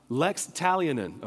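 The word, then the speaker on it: Lex Talionis: Talionin. You say you're saying that wrong. No, I'm not. Lex Talionin, okay? That's what it's called.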